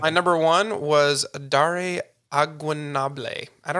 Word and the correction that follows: Ogunbowale: Yeah, I believe it's pronounced Ogunbowale. – Agunoble. – Yes. Okay. If I'm incorrect, then I apologize. That is my best effort. – Aaron does say in chat, Ogunbowale.